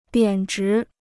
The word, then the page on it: 贬值: biǎn zhí